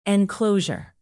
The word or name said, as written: enclosure